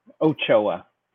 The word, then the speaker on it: Ochoa: Ochoa.